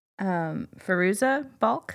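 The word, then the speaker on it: Fairuza Balk: Firuza Balk? Uh-huh. Is that how you say it? – Yeah.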